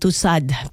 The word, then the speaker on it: Tussaud: Alam nyo yung kanya pong wax ay ilulunsad po sa Madame Tussaud, o yung Tussaud, pero pronunciation pala niyan ay silent D.